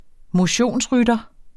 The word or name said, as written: motionsrytter